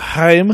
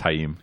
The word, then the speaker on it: Haim: Say it right. – Haim. – I think it's just Haim.